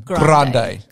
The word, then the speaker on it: Grande: Grande.